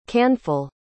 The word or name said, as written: canful